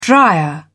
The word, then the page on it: drier: British English